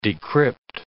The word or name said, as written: decrypt